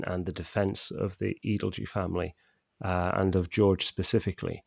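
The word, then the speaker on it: Edalji: And we now know it's pronounced Adelgee as a result of a letter that George wrote that's included in Shrabani's book.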